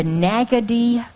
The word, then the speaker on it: Naggodee: This is the Nagaddy Creek Boys, and that's spelled N-A-G-G-O-D-E-E.